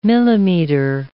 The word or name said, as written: millimeter